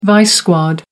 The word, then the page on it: vice squad: American English